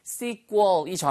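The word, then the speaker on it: sequel: 우리가 시퀄 하잖아요. 그게 sequel이 정확한 발음인데 그 단어를 쓰셔도 되고요.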